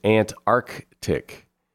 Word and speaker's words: Antarctic: You're supposed to pronounce that hard C in the middle. It's Antarctic.